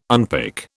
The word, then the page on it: unpick: British English